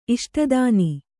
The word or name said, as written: ಇಷ್ಟದಾನಿ